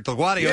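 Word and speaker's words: Delgaudio: His last name is DelGuadio.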